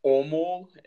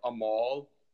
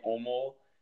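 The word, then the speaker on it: Åmål: One of the goalkeepers from it's actually pronounced the team that I played for in Sweden is Omol. It's it looks like it's spelled Amol, but with the A's and the circles over it, it's like a sound. So so IFK Omol, and it was a really good experience.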